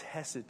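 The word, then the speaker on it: Hesed (חסד): It's the word hesed. Sometimes when you read it in English, it's spelled with a C. C-H-E-S-E-D.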